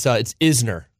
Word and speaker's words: Isner: No, most people get it wrong. It's Isner. – Isner. – Not Eisner, yeah.